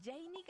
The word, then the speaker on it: Jenny: Ok, me encuentras como arroba Janie Capistrán. Se escribe así mi nombre: JeNNY Capistrán. Janie Capistrán.